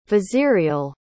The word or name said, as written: vizierial